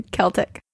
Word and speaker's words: Celtic: Celtic? – Yeah, Celtic.